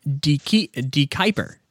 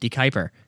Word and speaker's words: De Kuyper: Apparently, anything that's around 15% alcohol by volume, and it's De Kuyper. That's how you say it. De Kuyper is the company that makes the Pucker brand.